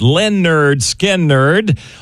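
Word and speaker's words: Lynyrd Skynyrd: It's an anniversary of the release of the album pronounced Leonard Skinnerd on August the 13th in 1973.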